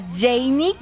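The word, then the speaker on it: Jeynny: Arroba Radio Disney MX, arroba Janie Capistrán. Mi nombre se escribe así, J-E y latina, doble N-Y, ¿ok?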